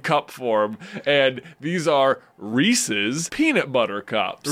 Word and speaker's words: Reese's: It's definitely Pieces. – It's definitely Reese's Pieces.